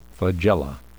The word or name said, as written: flagella